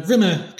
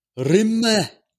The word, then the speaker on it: Rimmer: Not calling him Rimmer, because he puts the emphasis on the rim and makes him sound like a lavatory disinfectant. – It really does. Rimmer.